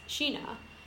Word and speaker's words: Shina: Oh my god, my my name like, um, it's it's spelled like China, but it's pronounced Sheena.